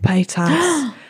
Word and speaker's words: Paytas: Paytas. – Yes. – Paytas in Amy Winehouse's music video, Tears Dry on Their Own. Yeah, was in that shit. – Wait, what? – Yeah, wait. Trisha Paytas was in an Amy Winehouse video.